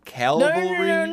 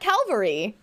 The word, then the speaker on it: cavalry: Cavalry. – No. Not Calvary. Specifically, Cavalry.